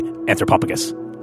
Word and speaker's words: Anthropophagus: That is the correct way to say Anthropophagus.